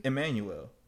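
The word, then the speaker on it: Immanuel: Emmanuel.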